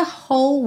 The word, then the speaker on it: whole: E no finalzinho, when you look at me and the whole world fades. Whole. Esse W do começo não é pronunciado, você pega direto do som do H, do, do arzinho, então whole. Não pronuncia o E.